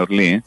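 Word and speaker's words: Orly: Allora io andai a Parigi per incontrare Rudy Fodere in un albergo vicino all'aeroporto di Orlì. Ho detto bene Orlì? – Orlì.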